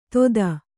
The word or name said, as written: ತೊದ